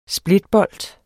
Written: splitbolt

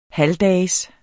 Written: halvdags

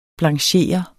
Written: blanchere

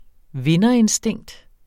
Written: vinderinstinkt

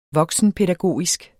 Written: voksenpædagogisk

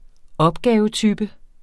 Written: opgavetype

